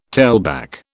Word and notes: tailback: British English

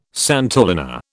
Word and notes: santolina: British English

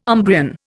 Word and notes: Umbrian: Indian

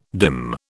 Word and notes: dim: British English

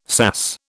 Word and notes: sass: British English

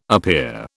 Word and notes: appear: British English

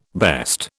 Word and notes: best: British English

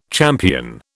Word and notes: champion: British English

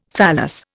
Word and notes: phallus: Indian

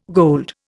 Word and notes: gold: Indian

